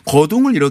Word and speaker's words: 거둥: 행차를 거동이라고 하지 않고 한문은 그렇게 쓰지만 거둥이라고 읽어야 읽 돼요. 둥. 예, 어. 맞습니다. – 거둥을 이렇게 하는 거를 행차를 구경했어요.